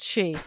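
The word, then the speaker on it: sidhe: Also, before we get too far away from it and too deep in, is it not pronounced she? – I don't know. I always, it's sort of cedar. Like, I'm going to have to look it up now. – Like, I thought it was like C. – Like Seder. – It may be. This is one of those things where I've only ever seen it written down. – Yeah, I think it's pronounced she. – It is pronounced she.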